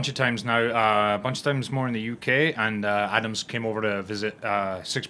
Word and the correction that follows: Adnams: Adams or Adnams? – Adnams, A-D.